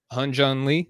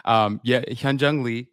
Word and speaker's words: Hyunjun Lee: Hunjun Lee. Did I say that right? Mm-hmm. – Hey, that's close enough. That's close enough. – More than close enough. Hunjun Lee. It's like that Y you got to throw in there, but it's hard to do the HY.